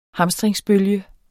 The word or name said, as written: hamstringsbølge